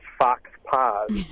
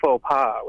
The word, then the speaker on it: faux pas: he pronounced fox pas. And his snooty classmate quickly rebutted him, saying, oh, you mean faux pas, with her nose held high in the air.